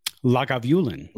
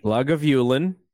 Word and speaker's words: Lagavulin: Lagavulin. – Lagavulin.